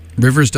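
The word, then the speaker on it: RIVVRS: As you can tell from that track, Run, Rivers does a wonderful job of blending folk pop with a touch of electronica.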